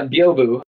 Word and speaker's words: Byobu: You have your own open source project called uh, and help me out here, Byobu. – Is that how you pronounce it? – Uh, yeah, Byobu.